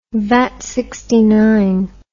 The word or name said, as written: vatman